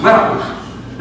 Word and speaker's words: Malchus: Marcus. Well, not the one Peter cut his ear off. Did I say that right? – Malchus.